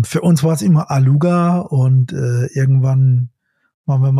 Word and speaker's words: Alugha: Ähm, es, also es kommt tatsächlich aus dem arabischen Raum und dort würde man es tatsächlich Alucha aussprechen, aber für uns ist es immer Aluga.